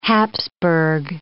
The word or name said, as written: Hapsburg